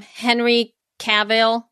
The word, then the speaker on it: Henry Cavill: Henry Cavill. Is that how you say his name? Henry? – Yes.